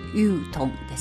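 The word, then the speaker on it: 宇通: 宇宙のウに交通の通と書いてユートンですねはい、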